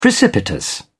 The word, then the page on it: precipitous: British English